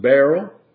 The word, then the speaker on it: beryl: In the fourth row, that's pronounced beryl, and an onyx, and a jasper, they shall be set in gold in their enclosings.